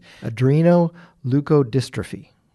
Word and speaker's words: adrenoleukodystrophy: doctors shared the terrible news about ALD, adrenoleukodystrophy. Did I say that right? – That is correct. All right. You did, yes.